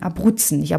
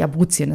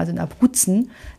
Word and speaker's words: Abruzzen: Und das war ein Dorf in Abruzzen, nicht Abruzien, das habe ich vorhin falsch ausgesprochen, also in Abruzzen.